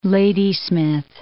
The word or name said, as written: Ladysmith